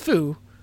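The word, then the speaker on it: Fu (風): The cover art of it features the kanji for wind, which is pronounced Fu, but has no relation to the kanji that's in Kung Fu.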